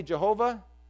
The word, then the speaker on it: Jehovah: And then also, when we say Jehovah, that's kind of a mispronunciation of the same thing.